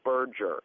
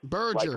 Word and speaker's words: Berger: Now, buddy, you said my name wrong a couple times now. It's Berger. – Berger. – Like merger with a B.